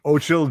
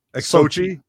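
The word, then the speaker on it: Xochitl: It's been reported that the actress, oh boy, Ochil Gomez. – That's how would Sochi. You say it? – Sochi. – Yeah, Sochi.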